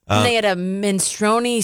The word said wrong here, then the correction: minestrone: soup that was fabulous. Yeah. What I could afford. – the minestrone? – Minestrone. Yes, I can't pronounce things.